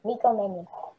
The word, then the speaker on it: Nico Mannion: I got Nico Mannion to the Nico, I don't know how to pronounce his name, but Nico Mannion.